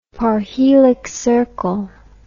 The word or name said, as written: parhelic circle